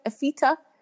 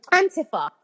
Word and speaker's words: Antifa: A-N-I-F-A. How do you pronounce that word? AFITA? The far left group. Antifa.